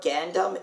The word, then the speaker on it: Gundam: But Gundam, it's a U.